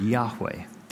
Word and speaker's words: Yahweh: The other one, the best pronunciation that we can come up with, is the word Yahweh, that you've probably heard if you've been around Christian circles at all. And the psalmist starts here by addressing Yahweh, even though he's in the midst of the depths.